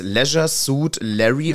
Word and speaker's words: Leisure Suit Larry: Aber ich habe vorhin nochmal nachgeschaut, es ist Leisure Suit Larry.